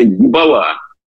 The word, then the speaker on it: ебала: Это слово на профессиональном языке называется «ебала». Ударение правильно ставьте в конце.